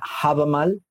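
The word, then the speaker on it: Hávamál: que se escribe e H-E-I-L-U-N-G, Heilung. O también Habamal, que se escribe H-A-V-A-M-A-L.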